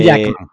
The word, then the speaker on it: Jackman: O Hugh Hackman, siempre lo pronuncio mal. – Eh, Hugh Jackman.